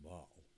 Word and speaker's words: Basel: I was in, it's called Basel, but it's Basel to you, people who don't understand, B-A-S-E-L, it's Basel. – Basel? – But you pronounce it Baal.